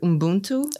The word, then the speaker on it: Ubuntu: The word Ubuntu.